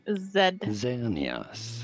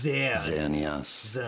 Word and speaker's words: Xerneas: This is to be said as Xerneas. Xerneas, not Xerneas. – Not Xerneas. – Z or Z sound. At the beginning, Xerneas. Xerneas.